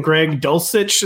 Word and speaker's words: Greg Dulcich: Greg Dulcich. Is that how you say his name, Corain? – That is how you say it.